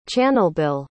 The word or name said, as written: channelbill